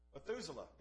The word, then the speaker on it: Methuselah: He fathered Methuselah. Hope I'm saying that right. Methuselah, there we go.